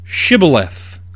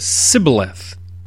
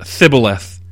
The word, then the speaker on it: Shibboleth: When he said no, they said to him, "Say Shibboleth." And he said, "Sibboleth." For he could not pronounce it right. Then they seized him and slaughtered him at the fords of the Jordan. At that time, 42,000 of the Ephraimites fell. Shibboleth, or some scholars think it might even be pronounced Thibboleth.